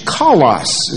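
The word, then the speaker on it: Kalos (καλός): Kalos is the way it's pronounced.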